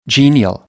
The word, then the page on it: genial: American English